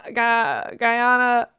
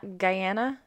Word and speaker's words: Guyana: They are native to the upland rainforest regions, again, of Northern South America, which includes Suriname, Guyana, French Guyana, Northern Brazil, and Southern Venezuela. Did I pronounce things wrong? – Guyana. – Guyana. Yeah.